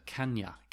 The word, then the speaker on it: can you: Can you is pronounced like one word, kanya, kanya, and you becomes ya, kanya.